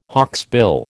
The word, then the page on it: hawksbill: American English